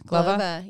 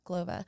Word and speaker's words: Glova: And wait, how do you pronounce it? Glova? – Glova. Yeah, Glova. It's super Polish. Glova.